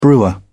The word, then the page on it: brewer: British English